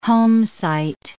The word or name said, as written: homesite